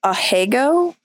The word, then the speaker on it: Ahegao: I am not 100% sure that I'm going to pronounce this correct, but Ahegao. Thank you, Cake. I appreciate that. I've always wondered. – Yeah, because everybody usually says it sounds like Asiago.